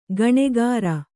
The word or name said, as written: ಗುಣಕಾರ